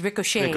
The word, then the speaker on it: ricocheted: Now, even if it's ed, you don't say ricocheted. It's ricocheted.